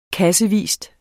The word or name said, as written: kassevis